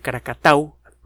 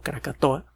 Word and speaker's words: Krakatau: Eh, acuérdese que hace poco recomendamos unos videos tomados desde drones de las erupciones violentísimas que experimentó Anak Krakatau, el hijo de Krakatau, mal pronunciado Krakatoa, en el 2018.